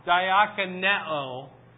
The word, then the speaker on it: diakoneo: And it's diakoneo. And that's just the verb form. Diakoneo. And it's a long O.